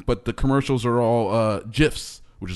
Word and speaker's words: GIF: Not GIF, but it's GIF, like the peanut butter. – Like the peanut butter. It's where they're repeating GIFs over and over.